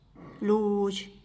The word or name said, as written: ludx